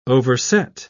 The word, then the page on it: overset: British English